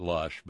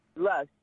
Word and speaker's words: Loesch: How do you pronounce her name? Loesch? – I call it Lush, but, you know. – Lush.